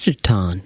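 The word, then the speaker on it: Jittaun: That was Let Me Know, LMK by Chitaun. That's spelled J-I-T-T-A-U-N. I may be pronouncing it incorrectly, but I see it as Chitaun. – It is Chitaun.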